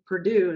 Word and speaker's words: Perdue: And I just want to always tell people that my last name, Perdue, is spelled P-E-R-D-U-E, like the chickens, because no one can ever find me when they try it the other way, like P-U-R, which is like the school.